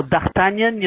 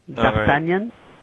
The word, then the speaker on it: D'Artagnan: And your pronunciation was pretty good. you know, D'Artagnan, you know, the three musketeers. You've seen, everybody's seen the movie. He right. – was the fourth musketeer, you know, D'Artagnan.